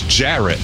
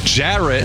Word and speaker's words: Jarrett: Ferret does rhyme with Jarrett, but my name is Jarrett. Jarrett.